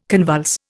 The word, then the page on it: convulse: Indian